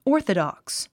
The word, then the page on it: orthodox: ɔ́ː r θədὰks; オーソドックス